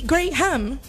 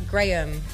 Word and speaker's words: Graham: And then you have the word ham, so it would be gray ham. – Graham. – No. – Graham. Yeah, only in English sometimes you have the silent H, Graham.